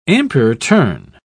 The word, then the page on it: ampere-turn: British English